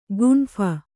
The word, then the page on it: ಗುಂಫ: gumpha